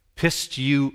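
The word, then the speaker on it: Pistuo (πιστεύω): Pistuo. o. That's how it's pronounced.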